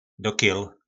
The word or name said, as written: dokjyl